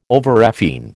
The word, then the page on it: overrefine: American English